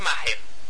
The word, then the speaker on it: Maher: B-I-L-L, Bill. Y Maher es M-A-H-L-E-R, ¿no? ¿O es Maher? – Creo que es Maher. – Maher, M-A-H-E-R.